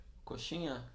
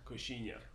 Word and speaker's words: coxinha: Coxinha. – Coxinha.